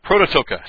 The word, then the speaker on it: Prototokos: Actually, this should be a long O. Prototokos.